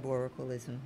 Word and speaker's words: cyborgicalism: Cyborgicalism, did I say it right? – Cyborgicalism, you're saying it right.